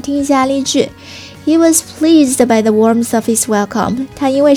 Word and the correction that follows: warmth: 刚刚那个 warmth 好像发音没有发到位哈，再重复来说一下。这里面的 th 它呢发音的时候是要咬住舌尖的， warmth warmth。再来听一下这个例句吧。